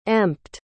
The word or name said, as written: empt